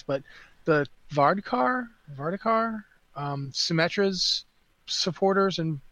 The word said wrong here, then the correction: Vishkar: backers, the company that they the Vishkar.